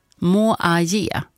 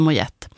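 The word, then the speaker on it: Moyet: Tack så mycket Stina då för att du skrev in att uttalet ska vara som i Allison Moaye. Moaye. Ja, Moaye. Inte Mojette.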